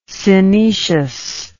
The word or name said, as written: synecious